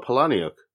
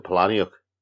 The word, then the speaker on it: Palahniuk: Okay, is it Palahniuk or Palahniuk? – It's Palahniuk. – Is it pronounced Palahniuk? – It is.